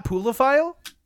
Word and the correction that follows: pluviophile: Jeez. If anything, I know for a fact you're pronouncing it wrong, but I'll pull it up for you. – Okay. – Obviously, they don't have a pronunciation for some reason. Pluviophile. – P- Pluviophile. Okay.